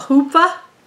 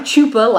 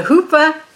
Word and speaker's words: chuppah: Afterwards, they would stand under, okay, right here, a chuppah. It's not a chupa, like I kept trying to say.